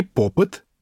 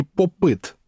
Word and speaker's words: поп-ит: По-русски это название, кстати говоря, прижилось с двумя ударениями. И попыт, и попыт. И однозначного правильного варианта нет.